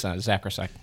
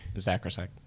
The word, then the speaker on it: Zakrajsek: Yeah, it's Zakrajsek. – Zakrajsek? – Zakrajsek.